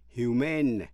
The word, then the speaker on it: Human: Humane, H-U-M-A-N-E, có lòng thương người hay là súc vật. Nhân đạo, nhân đức. Phát âm Human, nhấn vần đầu. Humane, nhấn vần thứ hai.